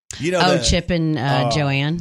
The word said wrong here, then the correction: Joanna: Joanna, sorry if I said her name wrong.